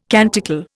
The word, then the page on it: canticle: Indian